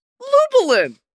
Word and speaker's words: Lubalin: And today I'm joined by, you know him, you love him, Lubalin.